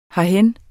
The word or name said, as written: herhen